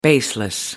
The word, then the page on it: baseless: American English